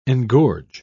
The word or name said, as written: engorge